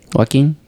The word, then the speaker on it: Joaquin: Why did you say Joaquin? – No, I don't know. Joaquin? – The J is silent.